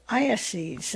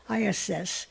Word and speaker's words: eyases: The Downtown Library installed a camera last year for ornithophiles to watch a pair of peregrines as they raise their eggs to Iasses. This is spelled E-Y-A-S-E-S, Iasses, on the 11th floor ledge.